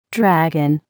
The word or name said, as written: dragon